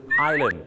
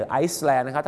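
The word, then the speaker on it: island: ก็คือไอแลนด์ไม่ใช่ไอแลนด์หรือไอซ์แลนด์นะ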